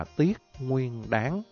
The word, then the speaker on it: tiết nguyên đán: Cho nên nếu mà đọc đúng cái phiên âm phải là tiết nguyên đán.